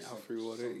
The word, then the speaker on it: Alfre Woodard: Alfre Woodard is playing the mom. I don't know how to pronounce her name. – Alfre Woodard, you said it right.